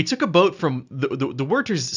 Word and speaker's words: Wörthersee: So essentially, think of Wörthersee as the or Wörthersee, as it's properly pronounced.